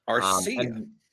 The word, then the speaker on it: Arcia: You skip over Riley for Orlando Arcia. See, I pronounced his name correctly this time. – Arcia.